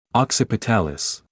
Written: occipitalis

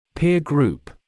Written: peer group

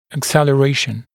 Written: acceleration

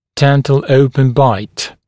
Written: dental open bite

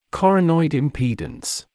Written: coronoid impedance

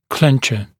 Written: clencher

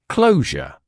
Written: closure

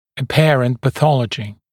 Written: apparent pathology

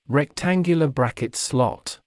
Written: rectangular bracket slot

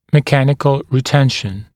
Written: mechanical retention

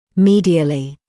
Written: medially

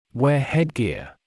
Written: wear headgear

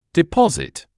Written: deposit

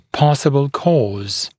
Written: possible cause

